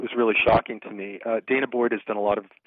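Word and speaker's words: danah boyd: And the Dana, I think it's pronounced Dana Boyd,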